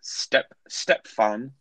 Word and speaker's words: Stepfan: The MVP was running back Stepfan. Yeah, I'm actually saying it right. It's spelled like Stepfan.